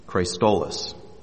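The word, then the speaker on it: chrestotes: The Greek word that is used here that is translated gentleness is Christolos. And I can spell it for you if you wish. Of course, yes, it's spelled C-H-R-E-S-T-O-T-E-S. C-H-R-E-S-T-O-T-E-S. Christolos.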